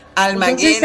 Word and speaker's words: Almaguer: Almaguer A-L-M-A-G de gato U-E.